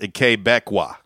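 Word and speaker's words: Québécois: Oh, he says it the way it's pronounced, Quebecois. – Okay. – Quebecois. – Okay. – Which is right. It looks like toi.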